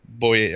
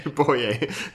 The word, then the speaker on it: Boye: Man har bra linebackers tycker jag, en hel drös bra linebackers och man har spännande cornerbacks i Jalen Ramsey och Bo Boye. Jag kan aldrig uttala honom, vad heter han? Houston? – Boye. Boye. – Boye. Boye. Är som den där Boye. reklamen. Äh, Karin Boye där på cornerback.